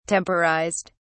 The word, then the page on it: temporized: British English